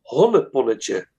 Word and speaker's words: Honneponnetje: And it's a movie, it's called Honneponnetje.